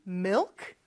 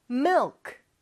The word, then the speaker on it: milk: Milk, 발음이 조금 어려울 수 있어요. Mill 하면서 을 발음할 때는 혀가 윗니 뒤쪽에 와서 쫙 붙지만 뒤쪽, 혀의 뒤쪽은 목구멍을 약간 닿는 느낌을 주죠. 그러면서 크 살짝 해주는 거예요.